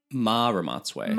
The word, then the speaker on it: Ramotswe: It follows, forgive me if my pronunciation is not correct, Madame Ramotswe? – Ma Ramotswe.